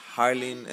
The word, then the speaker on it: Harleen: But then, since the pronunciation of Harlem was a little bit difficult for most of my community, everybody just pronounced it Harleen, changing the E for an I, and that's how I got named.